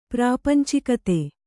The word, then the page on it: ಪ್ರಾಪಂಚಿಕತೆ: prāpancikate